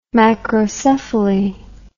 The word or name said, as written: macrocephaly